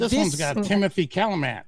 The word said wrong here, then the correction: Chalamet: First of all, it's Chalamet. All right? You know, like, you know, like... Pronounce it with me. Dune Chalamet.